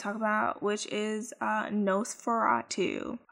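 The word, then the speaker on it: NOS4A2: It's Nosferatu, N-O-S, the number 4, the letter A, and then the number 2, but it is pronounced Nosferatu.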